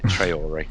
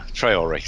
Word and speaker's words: Traore: Get his name right. Traore. right, write that down now. Traore.